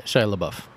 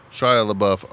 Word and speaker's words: Shia LaBeouf: Is it LaBeouf or LaBeouf? – Shia LaBeouf. – Shia LaBeouf. I'm sorry. I butchered his name for decades.